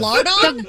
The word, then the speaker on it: lardon: L-A-R-D-O-N. How do you pronounce that word? – Well, I in French, I mean, lardon. Lardon is how they say it.